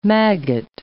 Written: maggot

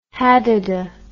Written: hadada

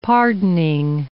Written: pardoning

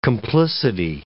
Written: complicity

